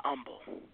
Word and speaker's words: humble: Some the H is silent. Not a lot of y'all not humble.